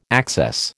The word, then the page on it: access: American English